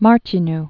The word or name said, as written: Martinu